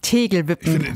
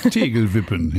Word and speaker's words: Tegelwippen: Das Ganze ist sozusagen ein Vorbild gibt es in den Niederlanden, das heißt Dorterewippen. Ich hoffe, ich habe es jetzt richtig ausgesprochen. Mhm. Dort wird tatsächlich schon seit 2021 entsiegelt. – Tegelwippen.